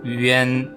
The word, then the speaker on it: 原: 原来是真的。原来是真的。Okay, let's review how to pronounce 原。原 is three sounds like combined together. 于 A, N, 原。Okay, repeat after me. 原。于 A, N, 原。原。Again, it's not like A, like one, like A sound here. No, it's like A.Yuan, Yuan.